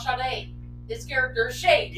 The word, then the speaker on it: Sade: You're shade. – No, it's pronounced Sade.